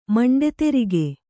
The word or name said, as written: ಮಂಡೆದೆರಿಗೆ